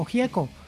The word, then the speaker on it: Gieco: A pesar de que su apellido es de origen italiano, en Argentina se pronuncia simplemente como Gieco.